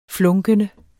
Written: flunkende

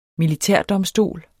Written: militærdomstol

